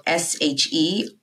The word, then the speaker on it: Sherrae: My name is spelled Sherrae, last name LaCHHU.